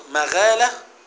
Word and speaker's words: مغالة: فالمقصود كان ابن صياد يلعب مع الغلمان عند أطم ابن مغالة، ومغالة تنطق مغالة بالفتح، فتح الميم وفتح الغين، بفتح الميم والمعجمة الخفيفة، معناها بطن من بطون الأنصار، يعني قبيلة من قبائل الإيه؟